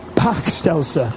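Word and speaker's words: Bachstelze: A Veldschnasser? Was that word quite right? It's pretty rubbish pronounced here. Pachtelser, thank you very much.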